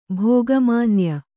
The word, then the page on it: ಭೋಗಮಾನ್ಯ: Kannada